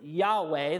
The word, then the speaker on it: Yahweh: We pronounce it Yahweh, though no one is actually 100% certain how it should be pronounced because of the way the Hebrew language works and the lack of vowels.